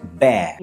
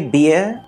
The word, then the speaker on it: bear: The animal called bear. We pronounce this word like this. Bear. Bear. We don't say beer, bear.